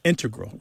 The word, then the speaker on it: integral: Yeah, but both correct, just to clarify here, both correct, but the mathematicians and math-using scientists are more likely to integral with the stress on the first syllable.